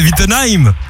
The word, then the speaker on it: Wittenheim: Comment ça va en direct de Wittenheim? – Oui. – Attends, est-ce que je l'ai bien prononcé? Parce que parfois je fais un peu de la merde. – Super bien prononcé.